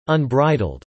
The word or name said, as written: unbridled